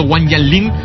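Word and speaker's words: Wang Jianlin: Hoy el empresario Wang Yanlin, ¿lo he dicho bien, Evo? – Lo has dicho muy bien.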